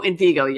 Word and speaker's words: Envigo: Because the reason it did pop in my head, I think, is because I talked to them about Enviro. Was that how you pronounce it? Which, Invigo. – oh, Envigo. Yeah.